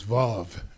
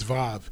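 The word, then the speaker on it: Vav (ו): Your word allows me to walk in freedom, W-A-W. – See, if Chip did a great job, but the Hebrew pronunciation of that is Vav, believe it or not. It's W, but it's Vav.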